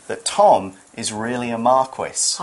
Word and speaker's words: Marquis: Marquis is how we'd say it in the UK. Marquis is more like France or Italy.